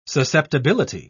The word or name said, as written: susceptibility